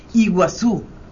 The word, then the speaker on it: Iguazú: Perdón, en idioma guaraní dice Iguazú, pero con la I de francés.